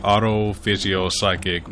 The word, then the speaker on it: Autophysiopsychic: You came up your collective, the Auto Physio Psychic Millennium Yes.